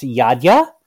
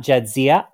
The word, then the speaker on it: Jadzia: Like it is from Eastern Europe, but it's pronounced Jadzia, not Jadzia.